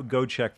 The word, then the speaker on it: Gojowczyk: Go, go check from Germany. Check boy, boy, that's a that's a very Go D- the J O are silent.